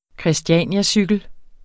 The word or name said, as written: christianiacykel